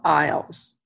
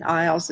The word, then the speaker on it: Iles: And the detective in charge is Detective Bob Iles. And Iles is spelled I-L-E-S, just like it sounds.